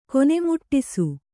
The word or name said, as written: ಕೊನೆಮುಟ್ಟಿಸು